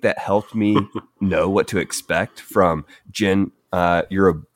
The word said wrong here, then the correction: Gen Urobuchi: I'm going to have to be the nerd here and say Gen Urobuchi. – Thank you. – There we go. Gen Urobuchi.